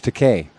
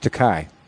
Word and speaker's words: Takei: I also, earlier, before I found out this, I found out that the proper pronunciation of George Takei is George Takei. I thought it was George Takei.